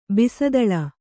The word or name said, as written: ಬಿಸದಳ